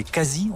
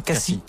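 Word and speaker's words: Khasis: Autre société où les femmes sont libres, les Kassis, on prononce comme ça Kassis.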